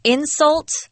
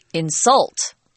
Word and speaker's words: insult: I wanted to point out, if you're using the noun form of the word insult, we actually pronounce it differently. Insult, and the verb is insult.